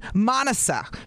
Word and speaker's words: Manasa: Manasa. So it's like Monica, but with an S Manasa? – Instead of a C.